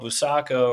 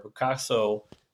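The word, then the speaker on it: Buçaco: And their first real meetup is at a town called Busaco or Bucasso, however you want to pronounce it.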